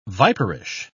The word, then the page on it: viperish: British English